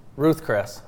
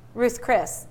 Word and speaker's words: Ruth's Chris: Ruth Chris. Say it again. – Ruth's Chris.